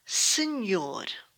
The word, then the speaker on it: Senhor: The letter H is silent at the beginning of a word, but sounds like a Y in the middle of the word. Senhor.